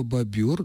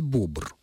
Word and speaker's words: бобр: Но однажды один из экскурсантов сделал мне замечание, сказав, что правильно говорить «бобр». Я стала рыться в словарях и справочниках и убедилась в том, что он прав. Бобр – животное, бобер – мех бобра.